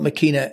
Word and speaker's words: Makeena: And it's spelled Makeena, M-A-K-E-E-N-A.